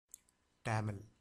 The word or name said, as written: tamil